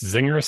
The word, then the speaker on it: Czinger: It's Zinger, C-Z-I-N-G-E-R, the Zinger 21C hypercar.